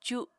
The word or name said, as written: cu